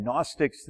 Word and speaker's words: Gnostics: Irenaeus, I-R-E-N-A-E-U-S. Irenaeus. And again, Gnostics, the G is silent, right? G is silent from the Greek word gnosis for knowledge.